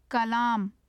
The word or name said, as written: kalaam